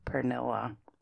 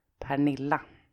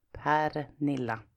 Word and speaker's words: Pernilla: But I guess it's because I'm so used to being called Pernilla in English when I was in the States, so it kind of stuck with me. But the Swedish pronunciation of my name is Pernilla. Pernilla. Not Pernilla. Well, anyways, thank you for the review.